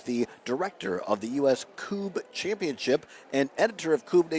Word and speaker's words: Kubb: It looks like Cub, K-U-B-B. Is pronounced Coob.